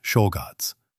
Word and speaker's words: Czolgosz: In this line of friends stood a fiend named Leon Czolgosz, pronounced Czolgosz.